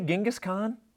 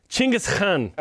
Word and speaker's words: Chinggis Khan: How did you say Genghis Khan? – Chinggis Khan. – Okay. – That's how you say it in Mongolian, apparently.